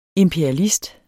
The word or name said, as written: imperialist